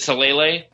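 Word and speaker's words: Talalay: It looks like Talele, Rachel Talele. – Is it Talele? – Yeah.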